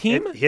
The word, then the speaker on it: heme: Yeah, it's pronounced heme.